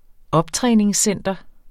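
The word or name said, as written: OPTRÆNINGSCENTER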